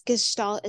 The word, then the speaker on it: Gestalt: So it's Gestalt. It's a German word.